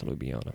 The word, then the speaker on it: Ljubljana: I think it is Ljubljana. – It's LJ at the beginning, and there's another LJ in the middle, which is a bit strange for English. But Ljubljana, Slovenia.